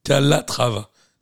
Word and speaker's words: Calatrava: Calatrava. C'est A-L-A-T-R-A-V-A. – Comme ça se prononce.